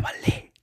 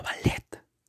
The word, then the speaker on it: ballet: se pronuncia ballet, no ballet. No sean nacos. Respeten la pronunciación original.